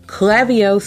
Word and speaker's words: Klaviyo: The last but not least app, Klaviyo. Klaviyo, spelled with a K. K-L-A-V-I-Y-O. Let's talk about Klaviyo because it's one of my favorite apps.